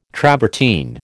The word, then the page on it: travertine: American English